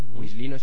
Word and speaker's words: Whistlino: Wislino se escribe WHISTLINO.